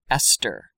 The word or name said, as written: Esther